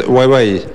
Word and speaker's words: Huawei: La empresa es Huawei. Acá la pronunciamos así.